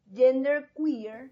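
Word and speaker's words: genderqueer: Bueno, la G, continuamos, es de gender queer.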